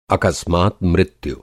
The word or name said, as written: अकस्मात